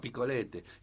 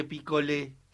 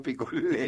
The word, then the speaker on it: picolé: Sí, se llamaba picolete. Y después vino un francés y dijo, no señor, se dice picolé. – Claro, y quedó picolé.